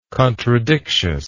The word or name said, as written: contradictious